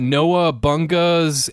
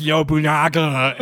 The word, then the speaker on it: Nobunaga: I'm like, hey, what is that Noah Bunga's Ambition? and I got the biggest, like, well, actually, that's pronounced Nobunaga, and, like, the biggest fucking eye roll you have ever seen in your life.